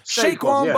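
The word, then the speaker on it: Saquon: But Shaquan, is that how you say it? – Shaquan Barkley.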